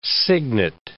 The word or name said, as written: signet